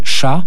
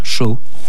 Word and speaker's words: Shaw: Ah, je dis chat, alors on va dire pourquoi il dit chat, pourquoi il dit pas chaud, parce que bon, Artie Shaw, ça fait un petit peu drôle, mais néanmoins c'est parce que Artie Shaw, qu'on aurait dû prononcer de cette façon en américain, était d'origine vraisemblablement polonaise et s'appelait Archaski. Alors donc, in short, en bref, Archaski, ça faisait chat et non pas chaud.